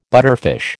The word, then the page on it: butterfish: American English